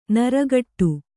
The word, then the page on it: ನೀರುಗೇಡಿ: nīrugēḍi